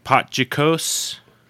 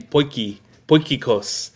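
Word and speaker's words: potjiekos: And do you know how to pronounce the, is it Potjikos? – It's a tough one. It's actually Poikikos.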